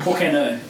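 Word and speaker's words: por qué no: Porqueno is how you say it. – Porqueno? – Yeah. Which is why not.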